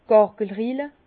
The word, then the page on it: d' Gargelrille: Bas-Rhin; Herrlisheim